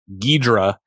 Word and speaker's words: Ghidorah: Monster of Monsters, Ghidorah, was its original international title, though in the United States, of course, Ghidra, the three-headed monster spelled G-H-I-D-R-A-H. So probably where the two-syllable pronunciation comes from.